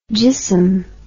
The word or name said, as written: jism